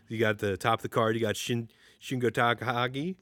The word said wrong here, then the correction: Shingo Takagi: Why did I say it like an idiot? Shingo Takagi. – You said it like a white guy.